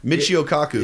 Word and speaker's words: Michio Kaku: I was always say his name wrong. Masao Keiku? – Michio Kaku.